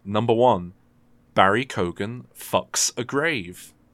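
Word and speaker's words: Keoghan: Apparently it's Barry Cogan. – Cogan, right, okay. – Apparently it's often pronounced Cohen, but he goes Cogan with, like, specifically.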